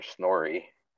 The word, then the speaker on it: Snorri: By Mr. Snorri, and yeah, that's how you pronounce it. – Snorri.